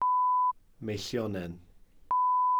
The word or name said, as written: meillionen